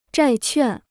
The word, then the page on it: 债券: zhài quàn